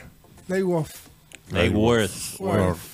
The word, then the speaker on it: Lake Worth: Oiga, saludos para Víctor Roa desde Lake Worth, Florida. ¿Sí se pronuncia? – Lake, Worth. ¿Lake? ¿Cómo se quiere? W-O-R-T-H.